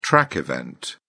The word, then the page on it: track event: British English